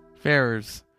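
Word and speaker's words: Ferrars: Ferrers.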